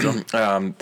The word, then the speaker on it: Bezos: Yeah, that's Bezos.